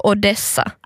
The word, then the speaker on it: Odessa: Odessa. Betoning på andra stavelsen. Så Odessa.